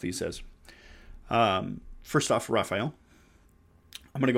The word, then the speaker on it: Raphael: Raphael, pronounced Raphael. Um, with these pronunciations, I'm going to present uh, pronunciations that are less um, less Latin English type pronunciations, and I'm going to stick more with the Hebrew pronunciations uh, which are a little older.